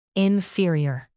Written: inferior

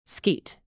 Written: skeet

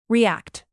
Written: react

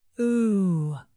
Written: ooh

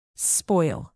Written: spoil

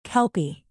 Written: kelpie

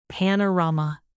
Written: panorama

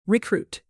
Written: recruit

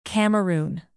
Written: cameroon

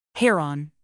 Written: heron

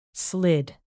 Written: slid

